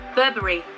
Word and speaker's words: Burberry: Burberry. Is that right? No. The correct one is Burberry.